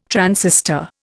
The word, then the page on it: transistor: Indian